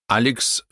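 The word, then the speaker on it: Alex: Алекс Фергюсон. Человек за маской легенды. Посвящается Адаму и Бену. Алекс. По-шотландски его имя произносится как Алек.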